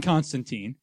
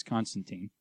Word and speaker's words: Constantine: I think they're actually going to call him Constantine, which is correct, but maybe not, because everyone says Constantine. – Well, that is also a name of a city and an empire, and... – Yeah, but Alan Moore says it rhymes with wine.